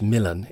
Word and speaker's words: Milan: Prospero comes from Milan, pronounced Milan in the play.